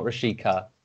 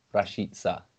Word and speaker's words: Rashica: Finally, I have looked at the pronunciation and basically, as far as I can tell, it's Milot, not Milo, Milot, Rashica. So not Rashica, Rashica.